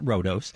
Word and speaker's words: Rhodos: It looks like Rhodes. – Actually, yes. Pronounce it Rhodos.